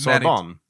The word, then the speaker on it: Sorbonne: And there, she studied at the Sorbonne. I also don't speak French. And I'm telling you, this word is coming up a lot, so get prepared for me to butcher it as Sorbonne. – Sorbonne. – Sorbonne. – Sorbonne. It's like S-O-R.